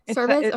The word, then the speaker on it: Servais: It's, it's S-E-R. Servais. okay.